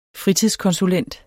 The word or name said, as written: fritidskonsulent